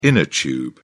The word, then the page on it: inner tube: British English